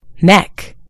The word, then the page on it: neck: British English